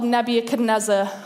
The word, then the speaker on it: Nebuchadnezzar: And that was being ruled by a king called Nebuchadnezzar.